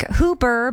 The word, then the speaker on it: Hooper: Hooper, spelled like Hooper, but pronounced Hooper because Utah, we love you.